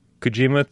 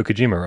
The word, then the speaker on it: Kojima: every time we don't say PPO properly because he's obviously going for the Hideo Kojima thing, and so apparently it's supposed to be Pepeo.